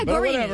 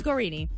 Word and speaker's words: Guarini: I don't even think you're saying it correctly. – Yeah, Guarini. It's Guarini.